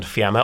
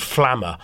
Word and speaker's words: Fiamma: We all know the brand Fiamma, often gets called Flamma or Filamma. Lots of mispronunciations. – It's Italian.